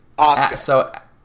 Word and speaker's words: Asuka: Just remember the U is silent. – Okay. – So it's Asuka.